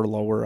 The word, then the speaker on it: Lower: And then obviously that kind of feel good story that Justin Lower. – Yeah. – Lower. I don't know how you pronounce it. – Yeah, you can't. It's spelled lower, so you just say lower.